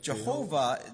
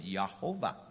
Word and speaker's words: Jehovah: So the Y, the Jehovah, the letter J is pronounced with a Y, so it's Yahovah.